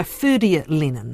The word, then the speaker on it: Ferdia Lennon: This is by Ferdia Lennon, if I've pronounced that correctly. – That's right.